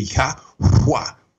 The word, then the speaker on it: Yahweh: And so we see the Yahweh, and it almost gives us three syllables, but we can also say it kind of fast down to two.